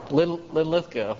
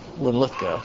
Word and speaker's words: Linlithgow: How do you say it? Linlithgow, yeah. I'll try to... thank you. Very handy to have a resident Englishman here to correct my Scots pronunciation. So, yeah, I get the accent's on the second syllable. Linlithgow, okay.